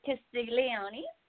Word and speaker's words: Castiglione: How do we say your last name? It is Castiglione.